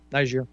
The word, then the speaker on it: Niger: But yeah, I mean, you know, Slattery was talking about Niger. I'm not sure I'm pronouncing that right. But Niger, yeah.